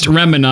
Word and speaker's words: Remina: Sincerely, Ramina Garamond of Gilgamesh, and I don't know. Remina, I guess is how it's... – P.S. It's pronounced Remina, I love not Remina.